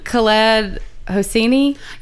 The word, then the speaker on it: Khaled Hosseini: I think it's still, it's, and how do you say his name? Khaled Hosseini. – Yeah, that's it.